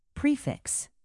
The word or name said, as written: prefix